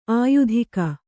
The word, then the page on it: ಆಯುಧಿಕ: āyudhika